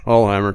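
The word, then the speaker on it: Alsheimer: How do you say his last name? – I think that's right. – Alheimer? – Yeah. – It's not Alzheimer's. – No, it's not Alzheimer's. – Alheimer, Charles Alheimer.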